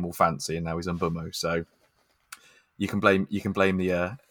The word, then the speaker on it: Mbeumo: Mbeumo. – Yeah, Mbeumo, yeah.